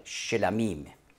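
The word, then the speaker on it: Shlamim (שלמים): Hebräisch heißt es Sevach Shlamim, oder ganz schön ausgesprochen Shlamim. Sevach Shlamim, Schlachtopfer.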